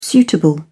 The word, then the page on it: suitable: British English